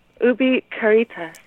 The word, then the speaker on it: Ubi Caritas: Ubi Caritas.